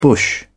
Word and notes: bush: British English

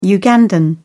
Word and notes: Ugandan: British English

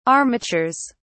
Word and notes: armatures: British English

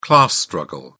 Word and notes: class struggle: British English

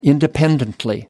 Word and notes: independently: American English

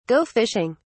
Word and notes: go fishing: British English

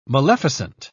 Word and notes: maleficent: British English

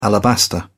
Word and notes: alabaster: British English

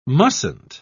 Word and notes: mustn't: British English

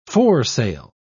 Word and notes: foresail: British English

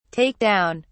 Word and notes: take down: British English

